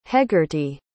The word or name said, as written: hegarty